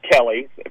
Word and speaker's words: Kelley: Yeah, so, you know, my last name is Kelly, but it's spelled K-E-L-L-E-Y.